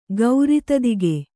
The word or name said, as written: ಗೌರಿತದಿಗೆ